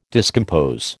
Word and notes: discompose: American English

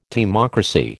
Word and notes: timocracy: American English

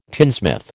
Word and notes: tinsmith: American English

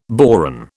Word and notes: boron: British English

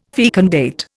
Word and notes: fecundate: Indian

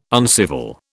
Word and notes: uncivil: British English

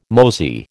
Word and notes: mosey: American English

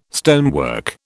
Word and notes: stonework: British English

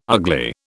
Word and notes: Ugli: British English

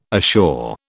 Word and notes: assure: British English

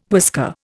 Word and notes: whisker: Indian

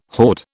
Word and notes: horde: British English

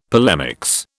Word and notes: polemics: British English

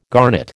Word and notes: garnet: American English

